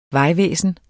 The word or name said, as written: vejvæsen